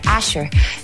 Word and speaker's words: Ashrr: La banda se llama Asher, pero se escribe A-S-H-R-R, Asher.